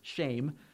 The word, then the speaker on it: shem: It's shame. That's how you pronounce it, which is funny. A good name is shame. But that's how you would pronounce that word, spelled S-H-E-M.